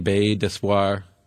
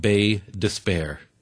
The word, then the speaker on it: Baie d'Espoir: You know how they pronounced the French expression, Baie d'Espoir? Sure enough, they called it Baie Despair. Baie Despair, from hope to despair.